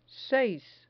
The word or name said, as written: seis